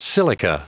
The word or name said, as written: silica